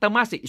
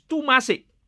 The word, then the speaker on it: Temasek: People have pronounced the word Temasek. It's not Temasek, it's Tumasek in Old Javanese.